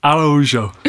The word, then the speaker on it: Araújo: Araújo.